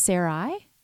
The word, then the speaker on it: Sarai: And I don't know, is it pronounced Sarai? – Before? Yes. – Is it? – Yeah.